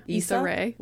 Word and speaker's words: Issa Rae: Issa Rae.